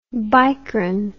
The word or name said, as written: bicron